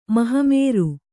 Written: ಮಹಮೇರು

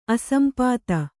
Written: ಅಸಂಪಾತ

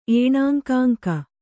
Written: ಏಣಾಂಕಾಂಕ

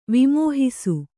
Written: ವಿಮೋಹಿಸು

